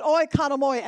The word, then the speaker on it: oikonomoi: But our word for dispensation comes out of the Latin translation, and we pronounce it oikonomoi. If you that are writing, it's O-I-K-O-N-O-M-O-I, oikonomoi, which means, now watch me, to govern or steward.